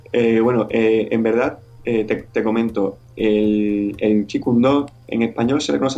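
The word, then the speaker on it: Jeet Kune Do: En chino cantonés se pronuncia Chi Kung Do.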